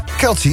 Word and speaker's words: Kelce: samen met Travis Kelce, zeg ik dat goed? – Kelce, ja. – Kelce.